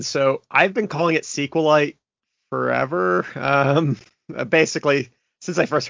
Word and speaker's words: SQLite: So it is pronounced SQLite,